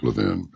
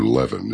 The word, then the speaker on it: Levin: Yes, the stepson of Fox News host Mark Levin. Oh, dear. Or Levin is what it really is. Levin. He says, that's what the people in Philadelphia, how the people in Philly pronounce it.